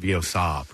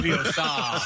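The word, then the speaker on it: Veozah: I think it's pronounced Vioza, Preston. – Shut up. – Vioza.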